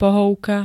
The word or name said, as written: pohovka